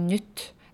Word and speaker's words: Nyt: I zamiast powiedzieć, restauracja nazywała się Nyt, to ja mówiłam Nyt. I dla Norwega to jest ogromna różnica, bo ja zapomniałam, że norweskie i, nasze polskie i po norwesku czyta się jako długie i.